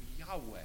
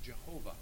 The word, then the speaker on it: Yahweh: Now, in the Old Testament, you probably know this, the phrase I am is intimately related to the covenant name of God, the name Yahweh. It used to be pronounced Jehovah.